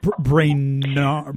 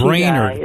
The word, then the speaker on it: Brainerd: It's called, is it Brainerd? How do I say it again? – Brainerd. – Oh, my gosh. – Brainerd.